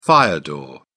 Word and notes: fire door: British English

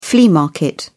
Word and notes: flea market: British English

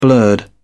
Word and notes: blurred: British English

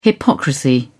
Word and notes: hypocrisy: British English

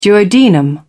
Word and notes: duodenum: British English

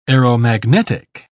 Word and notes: aeromagnetic: British English

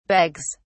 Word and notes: begs: British English

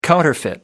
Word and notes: counterfeit: American English

